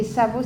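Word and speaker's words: Savaux: Aubert, ça s'écrit A-U-B-E-R-T. A-U-B-E-R-T et Savo, ça s'écrit S-A-V-A-U-X. S-A-V-A-U-X,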